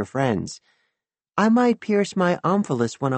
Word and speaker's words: omphalos: Full disclosure, I had to look up omphalos. You hit the first syllable, by the way, like you would an emphasis.